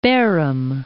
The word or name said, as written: Baerum